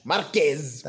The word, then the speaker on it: Marquez: Marquez. – No, it's Marquez, right? – Marquez. – He's Spanish. He's Spanish. – Oh. Marquez. – That's better.